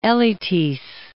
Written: Elytis

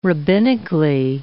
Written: rabbinically

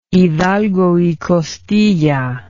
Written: Hidalgo y Costilla

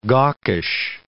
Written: gawkish

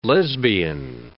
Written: lesbian

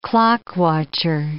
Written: clock-watcher

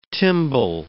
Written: timbale

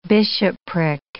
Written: bishopric

